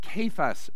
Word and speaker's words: Cephas: Then you got the Cephas party. Sorry. I'm going hard C. It's a kappa in the Greek.